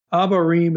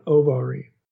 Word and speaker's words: Abarim: So Abarim in the Old Testament, if we looked it up in Hebrew, it's actually spelled with an O, an Ovarim.